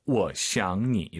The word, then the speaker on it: 我想你: 삼성이 세 개네요. 이럴 땐 주어가 반삼성입니다. 我想你. 이렇게 발음하시면 좋을 것 같아요.